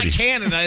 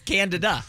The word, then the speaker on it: Candida: Yeah, it's not Canada, it's Candida.